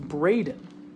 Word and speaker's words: Breden: My married name is Breden, spelt Bread-in, but pronounced Braden.